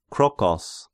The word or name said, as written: Krokos